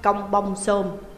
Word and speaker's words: Kampong Thom: Đơn vị của chúng tôi là đại đội 19 công binh trực thuộc trung đoàn 7 đóng quân ở tỉnh Kampong Thom, đọc là Công Bông Sôm.